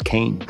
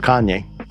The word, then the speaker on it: Kanye: I thought it was Kane that I found it's pronounced Kanye.